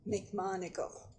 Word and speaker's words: McMonagle: McMonagle. – Spell that for name. – Me. – M-C, capital M-O-N-A-G-L-E.